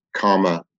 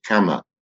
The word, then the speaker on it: Kama: Sometimes it's referred to by its Sanskrit name as Karma Loka. Karma is not like karma as in the law of cause and effect. It's actually pronounced Kama, K-A-M-A, and it means desire.